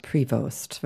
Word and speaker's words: Prevost: Also es gibt, wie gesagt, vorne die Betonung oder hinten, aber du würdest sagen, man spricht den neuen Papst mit bürgerlichem Namen aus. – Prevost, würde ich auf amerikanisch.